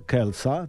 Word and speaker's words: Kelce: Tak, aby odebrać swojego chłopaka, Travisa Kelce'a, tak, się to wymawia tego Orsysia, i zabrać go do Australii.